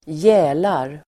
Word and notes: gälar: [²j'ä:lar]

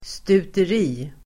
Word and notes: stuteri: [stuter'i:]

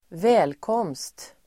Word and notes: välkomst-: [²v'ä:lkåm:st-]

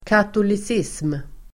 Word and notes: katolicism: [katolis'is:m]

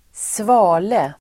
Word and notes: svale: [²sv'a:le]

